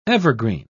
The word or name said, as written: evergreen